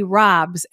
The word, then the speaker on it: ROBS: I have a story here, and we will get back to Susie and the ROBS, as in rob a bank is the right way to pronounce the acronym.